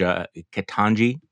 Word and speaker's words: Ketanji: I think, and I apologize in advance if I mispronounce the name, but the front runner judge, Ketanji, is that how you pronounce it? Mm-hmm.